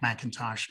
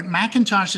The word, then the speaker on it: McIntosh: So you can find me pretty much anywhere, Mac McIntosh. But McIntosh is spelled M-C-I-N-T-O-S-H, no A, even though my first nickname, Mac, M-A-C, has an A, confuses people.